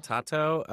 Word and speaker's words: Taito: Tato.